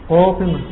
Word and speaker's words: فاطمة: فاطمة هكذا. لا يصلح أنك تحذف ليه نقطتين حتى ولو وقفت. ففاطمة عندي عند الوقف تنطق ايه؟ تنطق التاء، تنطق هاء، ولكنها ترسم بتاء مربوطة عليها نقطتان.